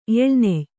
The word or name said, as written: ಎಳ